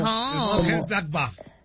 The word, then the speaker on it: Mönchengladbach: Mönchengladbach. – Del Mönchengladbach, así exacto, así como lo pronunciaría, así como lo pronuncia, así es. Así va el alemán.